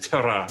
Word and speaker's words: terroir: Is that how you pronounce it? Terroir. – Terroir. – Terroir. Yeah. You have to have a hot potato in your mouth when you say it.